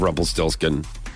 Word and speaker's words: Rumpelstiltskin: I don't remember the whole storyline of Rumpelstiltskin.